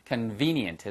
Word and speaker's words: convenient: 발음이 convenient예요. convenient. convenient. 해서 위치아가 아랫입술의 안쪽에 가서 붙으셔야 돼요.